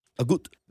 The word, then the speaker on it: Agut: I don't want the guy to text in every time when he thinks I say a T in there. – You have been putting a T on it. – Wimbledon. Agut, right? I said his name right? – Agut.